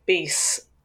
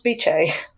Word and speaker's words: Biche: Because I'd been saying Bis, and then I was reading some letters, I think that might have been Antine's letters, and the very helpful editor just put in a little note saying, pronounce Biche. – Yeah, I was going to pronounce it Bis as well.